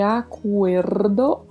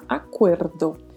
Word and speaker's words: acuerdo: É preciso pronunciar aí todas estas letras corretamente, acuerdo e este R com o vibrato, tá? Acuerdo.